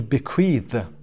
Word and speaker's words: bequeath: و to bequeath. Bequeath، صدای the در انتها تلفظ. و bequeath، استرس هم روی کیو هست که quiz خونده میشه.